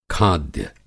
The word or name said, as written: खाद्य